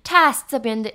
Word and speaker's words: task: task 这边的 a 呢是发。啊的音，它跟我们的 test 考试这一个字的发音不一样。这边的 test 考试呢是发微笑的嘴型 a，可是如果在任务的时候发 task 是发啊的音 task。